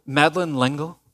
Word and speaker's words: Madeleine L'Engle: The author, Madeleine L'Engle, I looked it up, the pronunciation.